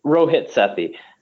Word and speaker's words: Rohit Sethi: Rohit Sethi.